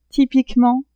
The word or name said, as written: typiquement